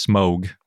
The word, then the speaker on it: Smaug: And apparently it's actually pronounced smog, not oh.